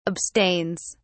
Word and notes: abstains: British English

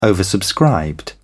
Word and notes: oversubscribed: British English

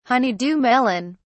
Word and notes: honeydew melon: British English

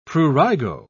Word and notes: prurigo: British English

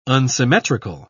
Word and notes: unsymmetrical: British English

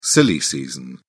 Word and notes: silly season: British English

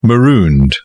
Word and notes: marooned: British English

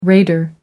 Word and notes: raider: American English

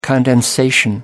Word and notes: condensation: American English

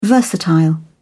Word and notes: versatile: British English

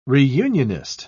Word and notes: reunionist: British English

